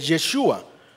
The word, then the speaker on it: Yeshua: Esa es la pronunciación hebrea de ese nombre, Yeshua.